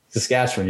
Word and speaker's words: Saskatchewan: We're just like, look, Saskatchewan, right? – Saskatchewan. Yeah. You nailed it.